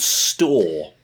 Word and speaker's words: Storr: But yeah, the guy is called Store. Not Store as in Superstore, but S-T-O-R-R. So it rhymes with Gnaw, as in the stock cubes.